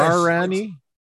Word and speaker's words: Darren: Darren-y? – It's Darren? Scottish in origin. Yeah, yeah. So Darren's hard in English.